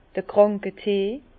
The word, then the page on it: d'r Krànketee: Bas Rhin; Herrlisheim